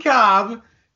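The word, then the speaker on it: Kolb: Kevin Cobb, proper way to pronounce his name, Kevin Cobb started two games in my career for Philadelphia.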